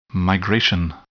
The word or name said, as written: migration